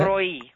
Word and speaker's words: El Roi: Is it Elroy E? Is that how you say it? – Elroy E. – Okay, and it's spelled E-L-R-O-I.